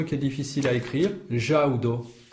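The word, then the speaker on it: ĵaŭdo: Il faut qu'il y ait un accent sur le U pour prononcer Jaudo. S'il n'y en avait pas, on prononcerait Jaudo. – Ah oui. – Avec un U long. Ouais, tandis que là, c'est le U accent, c'est un U. Le son ou, mais qui est court.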